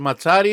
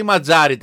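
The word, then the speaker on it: Mazzarri: 3-5-2 θα παίξει με τον καινούριο προπονητή, τον Βάλτερ Ματσάρη ή Ματζάρη τελικά. Μας φάγατε. Ματσάρη. – Όπως ένα μιλάει ιταλικά, θα έχει και τη σωστή προφορά. – Ματζάρη. Με δύο ζήτα, είναι ματσάζήτα.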